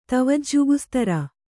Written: ತವಜ್ಜುಗುಸ್ತರ

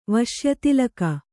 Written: ವಶ್ಯತಿಲಕ

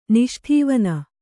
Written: ನಿಷ್ಠೇವನ

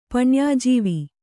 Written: ಪಣ್ಯಾಜೀವ

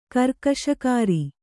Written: ಕರ್ಕಶಕಾರಿ

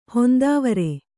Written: ಹೊಂದಾವರೆ

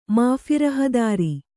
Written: ಮಾಫಿರಹದಾರಿ

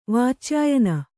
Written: ವಾಚ್ಯಾಯನ

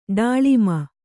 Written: ಡಾಳಿಮ

